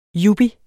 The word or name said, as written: YUPPIERS